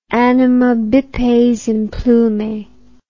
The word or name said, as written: animal bipes implume